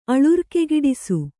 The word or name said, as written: ಅಳುರ್ಕೆಗಿಡಿಸು